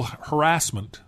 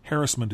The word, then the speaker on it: harassment: harassment. – Yeah. – Harassment is the correct way to say that, but I'm going to say harassment. – In Britain, maybe. No one here says harassment.